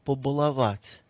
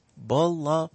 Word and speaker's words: побаловать: И по миновании поста, почему бы не побаловать? Обратите внимание, где я ставлю ударение в этом глаголе. Побаловать. Баловать. – На последнем слоге.